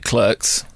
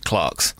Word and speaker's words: Clerks: Kevin Smith's first film, he pronounces it Clerks, but as a Brit, I would pronounce it Clarks.